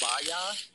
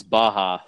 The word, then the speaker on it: Baja: I believe it's pronounced Baja. – It's Baja. – Soft J. Baja.